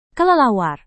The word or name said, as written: kelelawar